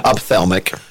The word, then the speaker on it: ophthalmic: Ophthalmic.